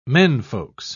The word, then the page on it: menfolks: British English